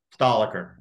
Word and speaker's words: Stolliker: Stolliker.